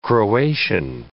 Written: Croatian

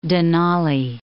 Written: Denali

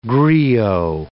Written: griot

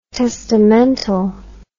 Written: testamental

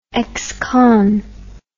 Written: ex-con

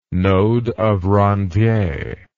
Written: node of Ranvier

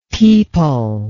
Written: peepul

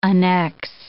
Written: annex

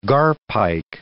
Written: garpike

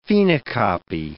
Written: phenocopy

